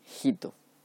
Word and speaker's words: Hito: Todos los emperadores del Japón llevan el como parte del nombre la terminación Hito, que se escribe H-I-T-O, ¿vale?